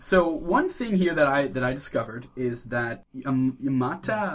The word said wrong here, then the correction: Iwata: Oh, Satoru Iwata. – Yeah, Iwata. – Oh, Iwata. What do you say, Imata? – I don't know. I can't pronounce that. I'm English and French. – You flipped the W? – Maybe I'm slightly dyslexic.